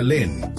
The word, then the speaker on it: L3N: Recuerda, se escribe L3N, se pronuncia Len, y este es su nuevo tema, Las Condiciones.